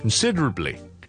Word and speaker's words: considerably: 当我们拉慢来读，听一次，那个重音在个 sid， okay， sid considerably， sid sid sid，那我们读好这个重音先。 sid 呢，这个是好像 Sydney 的 sid， sid sid sid Sydney， okay， sid。 So first syllable 很弱的， second syllable sid，所以两个捞埋一齐，我哋变咗 consid，后面听住系 durably，就系咁啦。所以 considerably 咁咧就可以读得好少少啦。呢个字希望咧帮呢个听众咧解答到呢个问题。